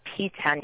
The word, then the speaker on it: PTEN: They called about 5 weeks later and said that she was, in fact, positive for a P10 mutation, which the name of the syndrome is Cowden syndrome, and P10 is spelled P-T-E-N, is a tumor suppressor gene.